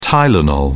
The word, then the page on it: tylenol: British English